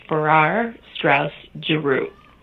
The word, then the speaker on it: Farrar Straus Giroux: I've heard others say the word different ways, but I have always felt that the way to say it is Farrar Straus Giroux. – Okay, let me see if I can get it. Farrar Straus Giroux? – Yes.